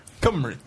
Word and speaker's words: Cymru: This is how you pronounce it. Cymru.